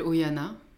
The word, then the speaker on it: Oihana: Je m'appelle Oyana, je le reprécise pour pas qu'il y ait de faute de prononciation.